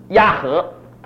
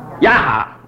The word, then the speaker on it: 亚哈: 那后来他篡位成为国王，那这个案例啊，就跟他打交道，就叫他的儿子亚和，亚和对不对？亚哈，OK，亚哈。